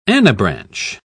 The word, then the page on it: anabranch: British English